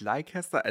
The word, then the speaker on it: Leicester: Leicester ist eigentlich Leicester.